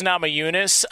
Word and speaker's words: Namajunas: Rose Namajunas.